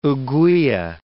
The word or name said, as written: ouguiya